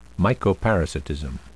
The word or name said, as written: mycoparasitism